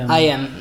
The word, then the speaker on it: IM: Dat is de Kia Tuesdays van de organisatie IM, zeg ik dat goed? – Yes. – IM.